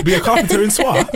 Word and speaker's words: Swansea: Swansea. Like, it doesn't... Is that how you say it? Swansea? – Swansea, yeah.